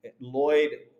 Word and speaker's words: Lloyed: My name has an E in it, Lloyd with an E. And I kept asking my mom throughout life, why did you throw an E in Lloyd? Everyone mispronounces it.